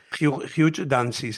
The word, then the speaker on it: Hugh Dancy: Hugh Dancy se llama, ¿no? Lo he dicho bien el nombre. – Sí, sí.